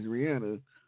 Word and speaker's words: Rihanna: Then with Rihanna, they was like, oh, for the longest time, we've been saying her name wrong. Her name is Rihanna.